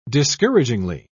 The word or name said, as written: discouragingly